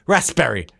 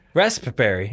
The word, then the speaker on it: raspberry: Why would the fuck would they put a P in there? Raspberry. – Raspberry.